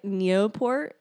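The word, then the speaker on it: Nieuport: So she learned to fly the Newport, it's like Neoport, that's Newport.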